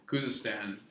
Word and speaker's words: Khuzestan: Khuzestan, and they tried.